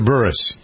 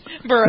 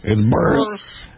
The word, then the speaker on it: Buress: Is it Buress or Burris? – Burris. – I think it's Burris.